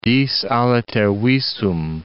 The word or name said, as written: dis aliter visum